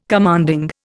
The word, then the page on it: commanding: Indian